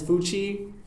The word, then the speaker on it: Fuchi: Hey guys, my name is Fuchi. It rhymes with Gucci, but I'm too broke to afford Gucci, so...